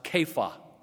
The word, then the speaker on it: Kepha: Aramaic has only one word for rock, and that word is Cephas, or sometimes pronounced Kepha.